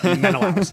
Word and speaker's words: Menelaus: Menelaus.